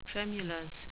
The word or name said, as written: tremulous